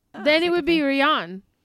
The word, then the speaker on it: Rian: It's Ryan. – Well, he spells it weird. – He does spell it weird. As someone who's seen him talk at like Comic-Con and stuff, he pronounced it Ryan.